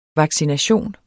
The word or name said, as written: vaccination